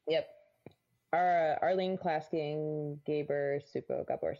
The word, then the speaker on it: Csupo: Yes, it's Supo. Or, like, Gabor Supo. I think that's how you pronounce it. C-S-U-P-O. Supo.